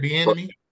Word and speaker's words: Bieniemy: Eric Bieniemy. – Bieniemy.